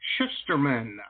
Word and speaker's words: Shusterman: Mr. Reed Shusterman. I believe I'm pronouncing his last name correctly. I hope so. Reed, I hope hey, I how's your name? – It, it it's a long U, but that's uh, far from oh.